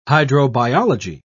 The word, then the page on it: hydrobiology: British English